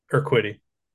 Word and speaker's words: Urquidy: Urquidy.